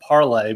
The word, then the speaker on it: Parler: It actually, what the dumbest thing is that it's a site dedicated to boomers, but it's a French word and it's pronounced Parlay.